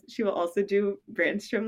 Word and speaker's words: Brännström: And she informed me that apparently we have been pronouncing some names wrong. Apparently it is Brännström. That's how it's pronounced.